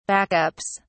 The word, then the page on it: backups: British English